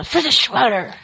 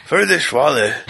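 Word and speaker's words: Friedrich Wöhler: Fica bravo e fala Friedrich Waller. – Friedrich Waller. Perfeito.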